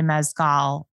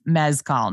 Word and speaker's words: mezcal: You're pronouncing it really funny. – How am I supposed to pronounce it? – You're making it like really, like I say mezcal, you're saying mezcal.